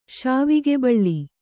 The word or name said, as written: ಶಾವಿಗೆಬಳ್ಳಿ